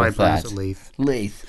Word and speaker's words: Leith: They pronounce it Leith.